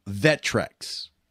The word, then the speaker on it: Vectrex: Google Vectrex. Now, it's spelled V-E-C-T-R-E-X, Vectrex.